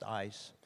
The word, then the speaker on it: eis: Now, the word for into that many Bibles are using is the Greek word eis, which is pronounced ice.